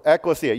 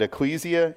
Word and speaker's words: ekklesia: When you see the word church, when Paul says church, this is the word, ekklesia. You can also pronounce it ecclesia if you like, but I'm going to go with the proper.